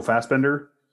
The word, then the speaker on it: Fassbender: And so, like, you know, I really enjoyed his performance, as well as butcher his name, Michael Fassbender.